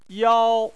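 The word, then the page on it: yao: tone 1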